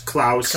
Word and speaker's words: Klaus: That is Claus as in Santa Claus. And it's spelled here K-L-A-U-S, Klaus.